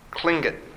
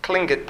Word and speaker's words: Tlingit: So after 10 years of being in this community, he left and he went to Sitka, where he found the, uh, how do you say this, Tlingit. Tlingit. They say it with a K. It's Tlingit population.